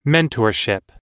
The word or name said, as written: mentorship